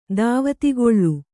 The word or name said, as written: ಧಾವತಿಗೊಳು